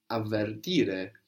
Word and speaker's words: avvertire: To warn in Italian is avvertire, two V's, avvertire.